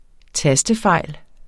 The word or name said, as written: tastefejl